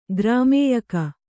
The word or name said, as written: ಗ್ರಾಮ್ಯಕ